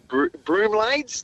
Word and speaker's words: bromeliads: my bromeliads, do you pronounce it? – Bromeliads, yep, close.